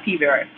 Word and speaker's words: P. vera: I've been to several hematologists over the years concerning my P. vera. – Mm-hmm. – Did I say that right? – Yeah, P. vera.